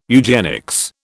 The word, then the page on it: eugenics: British English